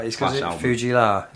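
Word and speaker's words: Fu-Gee-La: So it's called Fugee La, is that how you say it? – It's in the song, yeah.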